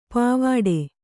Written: ಪಾವಾಡೆ

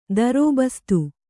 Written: ದರೋಬಸ್ತು

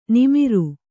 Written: ನಮ್ರ

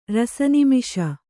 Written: ರಸನಿಮಿಷ

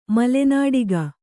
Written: ಮಲೆನಾಡುಗ

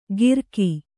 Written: ಗಿರಕಿ

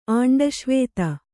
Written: ಅಂಡಶ್ವೇತ

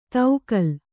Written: ತೌಕಲ್

